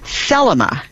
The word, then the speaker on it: Thelema: Well, we got a follow-up email, and what she was asking about is something called Thelma. T-H-E-L-E-M-A with the emphasis on the first syllable.